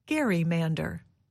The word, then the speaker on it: gerrymander: So the newspaper called the area a gerrymander. Over time, many people exchanged the hard G for a soft G.